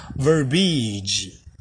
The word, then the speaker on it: verbiage: 美国人也常常会念错，就是我们刚刚讲的 verbose，对不对？verbose 是一个形容词，但是如果今天呢，我们要讲说一个冗词，就是多余的字，冗词这个东西它叫做 verb iage，verb iage，这个字有三个音节，大家注意听一下，它念 verbiage，verbiage。有没有中间是一个长音 b，然后再一个短 it，verbiage，verbiage，v e r b i a g e，但是常常美国人会把它念成就只有两个音节，就像 marriage 那样，它念成 verbiage，verbiage，这是错的，它念 verbiage。